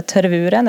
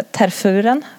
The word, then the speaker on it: Tervuren: Han är en belgisk vallhund, terverens eller tervuren eller terfuren som jag upptäckt att det uttalas.